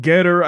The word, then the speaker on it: Gettr: And there's this new application called Getter, I guess is how you're supposed to pronounce this, G-E-T-T-R.